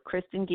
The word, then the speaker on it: Kristin: Kristen Geeslin, number two.